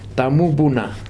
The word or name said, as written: tamupuna